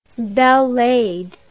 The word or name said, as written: belle laide